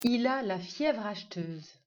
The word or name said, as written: Fièvre acheteuse